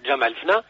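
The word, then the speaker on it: Jemaa el-Fna: Está bien, pues es la plaza famosa que se llama Jamalfna, que es muy conocida a nivel internacional y está declarada como patrimonio oral, declarado por la UNESCO.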